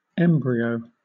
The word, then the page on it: embryo: British English